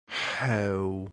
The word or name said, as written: heu